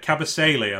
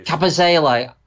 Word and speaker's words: Kabasele: I don't think, but Cabalse is it at Watford? – Uh, Kabasele, I believe. – Kabasele.